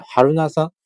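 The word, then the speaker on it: 榛名山: 嗯，还有还有一些乱七八糟的一些人都是这样，呃，包括这个真名山这个词儿呢，它用日语怎么读呢？日语读作，呃，哈鲁纳桑，哈鲁纳桑，啊，这这挺有意思啊，就是比如说我我读哈鲁纳桑这个这这个词儿哈，它可以是，比如说这个哈伦纳桑，呃，比如说这个日日日语里这个桑，大家知道什么意思吗？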